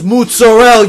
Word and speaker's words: mozzarella: It's pronounced mozzarella, you mook.